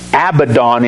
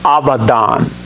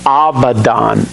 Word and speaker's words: Abaddon: And they had as king over them the angel of the bottomless pit, whose name in Hebrew is, and it's not pronounced Abaddon in Hebrew, it's pronounced Abaddon. When we see a B, if you pronounce it in Hebrew, it's pronounced like a V. Abaddon.